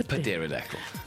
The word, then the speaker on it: pterodactyl: Because the P is silent. Pterodactyl.